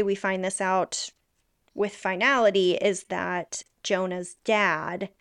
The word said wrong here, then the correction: Jonas: am I saying, it's Jonah, right? Jonas? – Yeah, it's Jonas. – Jonas. – There's an S, okay.